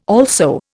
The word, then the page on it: also: Indian